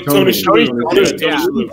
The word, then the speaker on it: Shalhoub: Tony Shalhoub.